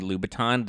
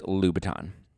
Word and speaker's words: Louboutin: And she was talking about, she's like, Labatons. We all love Labatons. Who doesn't love Labatons? She means Louboutins, as in Christian Louboutin, the red bottom shoes. They're pronounced Louboutin. Louboutin. I think it's Louboutin.